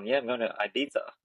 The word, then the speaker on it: Ibiza: I'm in the Baleares Islands, which before I was like, oh yeah, I'm in the Balearics, going to Ibiza. I've been told that's not how you pronounce it. It's Ibiza and the Baleares Islands.